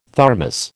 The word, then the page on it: Thermos: American English